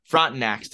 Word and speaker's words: Frontenacs: Frontenacs.